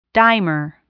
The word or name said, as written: dimer